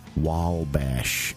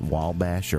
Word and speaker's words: Wabash: Wabash. Is that how you say it? Wabash or Wabash? – I don't know. – Is there an L in it? – No, there is not an L. – Then you don't say Wabash.